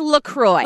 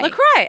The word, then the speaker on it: LaCroix: And it's pronounced Lacroix. – Lacroix.